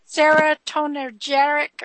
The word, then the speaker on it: serotonergic: When they talk about serotonergic, or how do you say that? – Serotonergic medication, what's inside that?